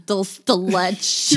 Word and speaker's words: dulce de leche: I don't even think when I correct you, it's just dulce de leche. – Dulce de leche.